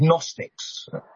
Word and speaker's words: Gnostics: Now, the major threat to the church from this Greek sort of Platonic background are people called Gnostics. That's G-N-O-S-T-I-C-S. So the G is silent.